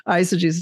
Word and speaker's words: eisegesis: Even though it's spelled EI, it's really an I, eisegesis.